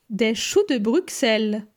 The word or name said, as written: Des choux de Bruxelles